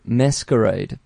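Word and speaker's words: MSQRD: It's called Masquerade, but it's spelt without its vowels, so it's M-S-Q-R-D.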